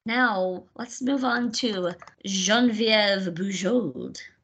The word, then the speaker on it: Geneviève Bujold: And that's the proper way to pronounce her name, the French way. – Oh. – Geneviève Bujold. If you're American, you might pronounce it Genevieve, and that's just a terrible butchering of that name.